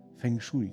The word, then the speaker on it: Feng Shui: Feng Shui, genau. Also Feng Shui ist, der, ist die richtige Aussprache.